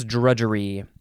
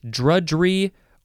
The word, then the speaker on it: drudgery: Here's drudgery. You can say it with two syllables or three syllables. Drudgery or drudgery.